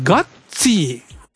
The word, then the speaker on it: Gutsy: ガッツィ、現代なんだそうですけどね、 GUTSY と書いて、ガッツィというタイトルです。